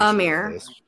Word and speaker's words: Ymir: Emir.